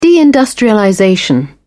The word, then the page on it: deindustrialization: British English